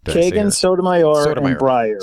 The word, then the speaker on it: Breyer: Yeah, I guess another thing is these leftist justices, Kagan, Breyer.